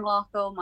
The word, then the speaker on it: Magliocco: So they can get me on just Sarah Malocco, my own name, but it's spelled very awkwardly.